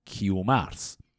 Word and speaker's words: کیومرس: گیومرت شکل قبلی تلفظ کلمه ای که امروزه میگیمش کیومرس